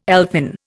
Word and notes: elfin: Indian

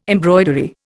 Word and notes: embroidery: Indian

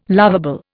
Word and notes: loveable: Indian

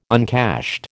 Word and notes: uncashed: American English

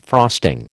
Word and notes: frosting: American English

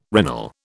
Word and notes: regnal: British English

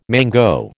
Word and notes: mango: American English

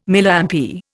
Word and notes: Milliampere: Indian

